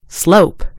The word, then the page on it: slope: British English